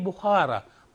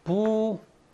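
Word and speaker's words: Bukhara: 그쪽에서 어떻게 읽느냐? 이 흐를 갖다가 하로 읽어요. 그래서 어떻게 읽냐면 부하라라고 읽어요. 부하라, 부하라라고 있습니다.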